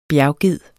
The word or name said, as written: bjergged